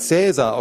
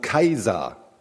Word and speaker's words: Caesar: Die alten Römer haben nämlich den Namen ihres großen Feldherrn vermutlich gar nicht Caesar ausgesprochen, sondern Kaiser.